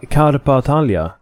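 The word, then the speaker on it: Kárpátalja: Jag vet inte ens hur man uttalar Carpatalia. – Ja, det låter rätt.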